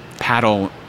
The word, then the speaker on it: padel: It's P-A-D-E-L. Okay. it's funny. They pronounce it paddle in most countries in Europe, which gets very confusing for us in the U.S. that play paddle.